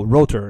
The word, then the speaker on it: rotor: Router? – Router? – Yeah, no, rotor, like ROTOR. Sorry for the pronunciation.